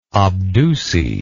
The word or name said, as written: obduce